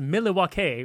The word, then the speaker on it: Milwaukee: It's pronounced Milwaukee, which is Algonquin for the good land.